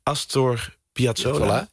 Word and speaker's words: Astor Piazzolla: Astor Piazzolla.